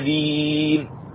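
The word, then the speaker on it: دِينِ: دين وليس دين، تكسر الدال ولا تفتح،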